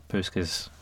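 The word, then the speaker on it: Puskás: Puskas Award.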